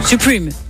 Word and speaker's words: Supreme: Supreme, si vous souhaitez le prononcer à l'anglaise.